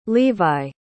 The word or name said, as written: levi